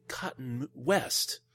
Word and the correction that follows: Colton West: No, I'm sorry. I mispronounced that. I apologize. Colton. Colton West. That's a bold move, Colton. Let's see if it pays off for him. I know it's a bold move, Cotton, but see, I mispronounced it twice now.